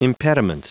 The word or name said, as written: impediment